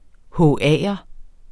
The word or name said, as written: HA'er